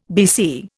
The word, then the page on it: BC: Indian